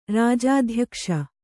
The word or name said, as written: ರಾಜಾಧ್ಯಕ್ಷ